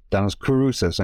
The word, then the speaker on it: Denis Kouroussis: Yes, I am Denis Kouroussis, and you pronounced it very well.